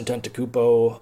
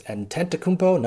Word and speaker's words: Antetokounmpo: So it's Antetokounmpo, not Antetokounmpo, which is another fun Milwaukee fact.